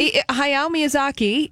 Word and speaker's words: Hayao Miyazaki: Hayao Miyazaki.